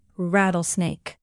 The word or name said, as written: rattlesnake